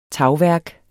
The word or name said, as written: tagværk